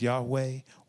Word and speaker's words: Yahweh: God's name and best pronunciation we know is Yahweh, YHWH.